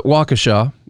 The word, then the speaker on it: Waukesha: I read that pronunciation from someone who is reportedly from the area, and so I'm going to call it Waukesha because that's how they said it is pronounced.